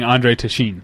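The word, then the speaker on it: Téchiné: I've been saying Andre Tashin. Tashin. – Okay. But I. Um, I could be completely wrong. That sounds right.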